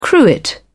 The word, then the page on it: cruet: British English